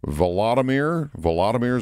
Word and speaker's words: Volodymyr: Vladimir Zelensky. – Volodymyr Zelensky, yeah.